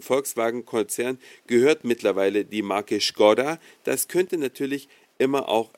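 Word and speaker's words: Škoda: Vielleicht habt ihr es ja gehört, Skoda wird in Deutschland immer Skoda gesprochen, obwohl die Marke eigentlich Skoda äh, ausgesprochen wird.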